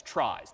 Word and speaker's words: trie: So this is a data structure called a trie, T-R-I-E, which is inherited from the word retrieval, which is not pronounced retrieval, but that's what the world calls these things, tries, T-R-I-E.